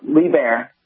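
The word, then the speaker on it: Liebherr: You're close. It's LeBaire, but it depends on if you ask an American or a German. But if you stick with LeBaire, you're pretty close.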